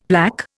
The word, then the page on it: plaque: Indian